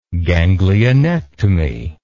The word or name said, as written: ganglionectomy